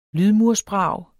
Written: lydmursbrag

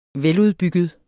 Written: veludbygget

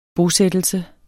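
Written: BOSÆTTELSE

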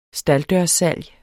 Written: stalddørssalg

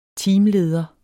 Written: teamleder

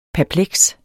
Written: perpleks

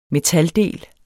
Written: metaldel

